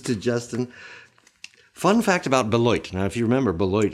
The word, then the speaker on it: Beloit: about Beloit, I have been told that the locals say the name of their city sounds like a quarter falling into a toilet bowl. Beloit.